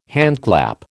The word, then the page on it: handclap: American English